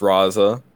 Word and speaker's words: Raza: Pronounced Raza. That's R-A-Z-A.